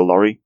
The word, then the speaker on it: Lorre: It says here, his voice is an impression of actor Peter Law. That's how it's pronounced. Peter Lorre.